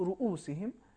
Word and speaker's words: رؤوسہم: بہت سے لوگ اس کو زبر کے ساتھ پڑھتے ہیں, ہم نے بھی سنا, لیکن یہاں پر صحیح تلفظ رؤوسہم پیش کے ساتھ ہے.